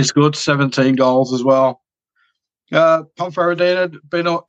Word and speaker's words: Ponferradina: And it's Ibiza against Ponferradina. I hope I'm saying that right. – Oh, you are. Yeah, that's good pronunciation.